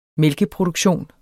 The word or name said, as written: mælkeproduktion